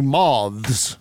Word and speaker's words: Moths: Yeah, give it a hard TH. Moths. – Okay, yeah. Sounds good. Mm-hmm. Yeah. Link in the description to support the Gypsy Moths.